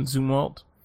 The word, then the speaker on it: Zumwalt: Zumwalt.